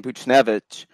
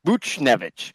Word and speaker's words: Buchnevich: Well, you have an RFA and Buchnevich, and uh, you still have uh, But after those top two, you know, I might have mispronounced that one, but oh, you, you got that one all right. Buchnevich.